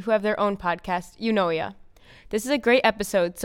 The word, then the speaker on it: Eunoia: Well, the name of our podcast is You Know Ya Podcast, which is spelled E-U-N-O-I-A.